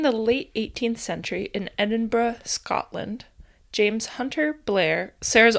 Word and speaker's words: Edinburgh: It's not Burrow. – Fuck. – I'm like quite certain it's like Edinburgh.